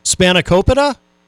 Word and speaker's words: Spanakopita: Spanakopita? – Yeah.